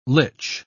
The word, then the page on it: lych: British English